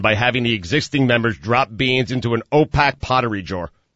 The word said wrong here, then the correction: opaque: Man, it would have sounded like you weren't reading that at all if you had actually pronounced it the way it should be pronounced, which is opaque.